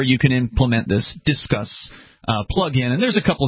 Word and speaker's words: Disqus: Their site, you know, on the commentary, because you have to go through something called Disquis, and Disquis has this elaborate feature. Discuss, is that what it's actually called? – Okay. D-I-S-Q-U-S. – It's pronounced Discuss.